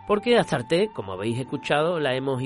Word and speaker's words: Astarte: Astarte, la diosa Istar de los textos de Mesopotamia, en griego se decía Astarte, tal como lo pronunciamos en castellano.